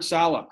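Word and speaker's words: Saleh: Was it Sala? How do you pronounce the last Robert name? Robert Sala. – It's Robert Sala.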